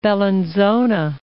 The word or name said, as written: Bellinzona